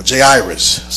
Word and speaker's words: Jairus: And he says, Behold, there came a man named Jairus, or Jairus, some pronounce it that way.